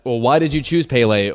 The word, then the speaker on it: Pelé: If in the Brazilian Portuguese they would say Pelé, then I would like to say it properly.